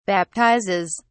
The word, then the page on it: baptizes: British English